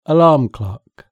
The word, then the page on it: alarm clock: British English